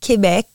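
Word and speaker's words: Québec: Well, a lot of people say Quebec, but I say, I mean, in French, it's Quebec.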